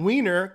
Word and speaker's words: Wien: The term Wiener comes from Vienna, Austria. And Vienna is pronounced Wien in German, which is spelled W-E-I-N or however you pronounce it.